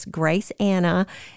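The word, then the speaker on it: GraceAnna: It's a double name, but it's only three syllables. It's Grace Anna.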